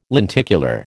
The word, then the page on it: lenticular: American English